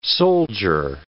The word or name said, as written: soldier